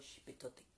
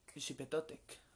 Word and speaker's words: Xipetotec: Moctezuma comía pozole en honor al dios eh, Xipetotec. No sé si lo estoy pronunciando bien, por favor. Xipetotec. Xipetotec. Ah, ah pues sí.